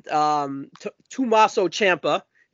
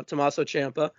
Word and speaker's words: Tommaso Ciampa: Bron Breakker and Tommaso Ciampa. Did I say it right? No, Tommaso Ciampa.